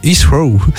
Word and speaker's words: Heathrow: Londres Heathrow. – Oui. – Je le dis bien. – Oui, je dis bien.